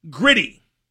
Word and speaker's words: Gritty: He's called Gritty, which rhymes with not pretty,